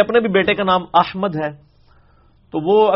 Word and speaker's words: احمد: احمد لفظ تو نہیں, یہ احمد ہے, میم کے اوپر زبر ہے.